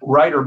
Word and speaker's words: Rytr: Rytr, by the way, is spelled R Y T R.